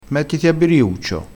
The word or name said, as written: biriuccio